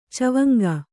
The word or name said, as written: ಚವಂಗ